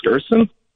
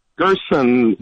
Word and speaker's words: Gersten: My name is Gersten. – Gersten? – Gersten. Well, rhymes with person.